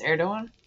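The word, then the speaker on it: Erdoğan: It has a similar suffix to the president of Turkey. It's pronounced Erdogan.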